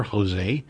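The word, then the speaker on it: José: And all of those are basically as written, older, as in younger, older, Jose. – With the accent on the E.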